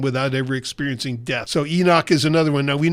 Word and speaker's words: Enoch: Enoch.